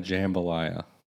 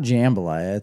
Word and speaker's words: jambalaya: What's the difference between a gumbo and a jambalaya? – It's not jambalaya. It's jambalaya. – Jambalaya, jambalaya. – No, you put the wrong emphasis on the wrong syllable. – Yeah, I think it's jambalaya.